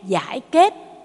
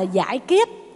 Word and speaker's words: giải kết: Giải kết, giải kết, giải oan kết. Kết là thắt chặt các tội lỗi, thì lát nữa chúng ta tụng đọc là giải kết, chứ không phải là giải kiết.